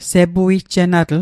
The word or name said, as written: sepuijjenatl